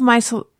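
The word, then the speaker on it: micellar: I've heard of my cellar, that's how you say it, water being like such a amazing thing.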